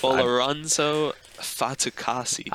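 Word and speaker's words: Folorunso Fatukasi: I cannot pronounce his first name, so I just Folorunzo Fatukasi. – I think you got it.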